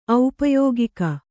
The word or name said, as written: ಔಪಯೋಗಿಕ